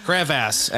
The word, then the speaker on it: crevasse: Crevasse, actually, is the preferred pronunciation.